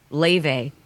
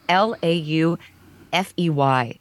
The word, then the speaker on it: Laufey: He's a he's a young guy, and uh, I just he did a great performance as well as uh, there's another one Laufey. Her name is spelled A uh, L A U F E Y, so it doesn't look like she's Icelandic. She's uh, her name's Laufey, and she kind of does like a jazz, indie rock, classical combination.